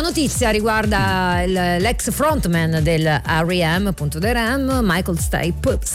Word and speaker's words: R.E.M.: abbiamo sempre chiamato REM, in realtà R.E.M.